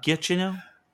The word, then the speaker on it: Giacchino: Is that right, Giacchino? – Yes. – It's a hard G. – The G is not silent.